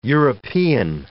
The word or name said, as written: European